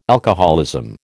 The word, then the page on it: alcoholism: American English